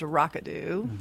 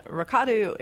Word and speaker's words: Rikadu: So where does the name Rikadu come from, and what's the most common mispronunciation of it? – Well, the most common mispronunciation is Rakadu. Mm-hmm. Then Rikadu is, it's an old Assyrian word, and it means to celebrate.